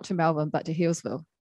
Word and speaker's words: Healesville: Is it how you say it? Healesville? – Yeah, Healesville. Yeah.